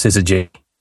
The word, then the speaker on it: SYZYGY: SYZYGY, pronounced scissor G.